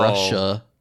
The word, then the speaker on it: Rusher: Russia, but it's spelled like R-U-S-H-E-R.